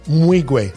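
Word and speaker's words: Nwigwe: Mwigwe. N-W is Mwigwe.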